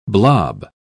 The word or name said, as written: blob